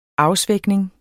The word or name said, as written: afsvækning